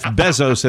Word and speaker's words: Bezos: By the way, to Beto O'Rourke and Jeff Bezos, may we all collectively pronounce the E as an E? – So Bezos. – Can we say Jeff Bezos and Beto O'Rourke? – Mm-hmm.